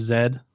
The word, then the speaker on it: Z: So like seeing him as I recently saw The Lost City of Zed. – Uh-huh. – Or Z, depending mm-hmm. on how you want to pronounce it, but they pronounce it Zed.